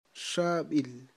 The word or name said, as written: xab'il